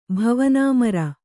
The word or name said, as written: ಭವನಾಮರ